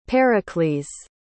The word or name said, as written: Pericles